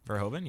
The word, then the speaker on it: Verhoeven: Verhoeven, yeah.